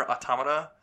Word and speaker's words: Automata: I played more of Nier Automata. – Mm-hmm. Joe, you want to give another shot at pronouncing that one? – Automedia? – Nope, wrong.